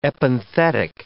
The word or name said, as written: epenthetic